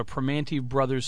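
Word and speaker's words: Primanti Brothers: People in northeastern Pennsylvania have no clue about a Pramonti Brothers sandwich, and you've said it right.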